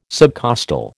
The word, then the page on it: subcostal: American English